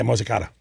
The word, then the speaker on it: Mozzicato: Yeah, Mozzicato. – Mozzicato? Oh, Mozzicato. – Mozzicato. – Well, if you're from New Jersey, you always say the A at the end.